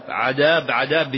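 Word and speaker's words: عداب: هذا مثل الأخ الباحث في السنة أيضا عذاب الحمش، بعضهم يقول عذاب وبعضهم يقول المحمش وبعضهم يقول الحمش وبعض يقول الحمش، وهو كما ينطقها أهل البلد عذاب الحمش، ما هو عذاب، نعوذ بالله من العذاب، عذاب بالدال المهملة.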